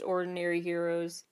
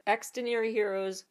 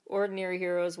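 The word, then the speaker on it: Xdinary Heroes: And lastly for this category, this new group from JYP Entertainment, I believe it's pronounced Ordinary Heroes, but it's spelled with an X instead of an O at the beginning. But Extraordinary Heroes, Ordinary Heroes, whatever.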